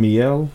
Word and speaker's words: Miele: Miele. – Miele. – Yeah. – I can't. The white hair girls keep saying Miele. So I'm like, yeah. I don't know if Miele is right,